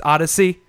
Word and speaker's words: ODY-C: It's spelled ODY-C, so it's Odyssey.